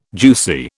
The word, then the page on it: juicy: British English